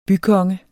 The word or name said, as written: BYKONGER